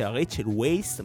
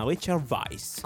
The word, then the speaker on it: Rachel Weisz: Ho scoperto, dopo 20 anni e passa di carriera nel cinema, che non si dice Rachel Weisz, ma Rachel Weisz. – Come la birra.